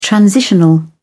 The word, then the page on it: transitional: British English